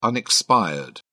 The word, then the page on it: unexpired: British English